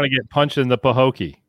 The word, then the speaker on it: Pahokee: Quad is a 23-year-old from, now, am I saying this right, Mr. Floridian? Pahokee? P-A-H-O-K-E-E. Pahokee, Florida? – Sounds good to me.